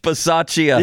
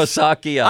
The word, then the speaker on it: Bisaccia: It was like Rich Scarlacchia or something. I'm close. How do you pronounce his name? – Rich Bisaccia. – Yeah, I was very close. – Or Bisaccia.